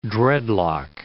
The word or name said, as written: dreadlock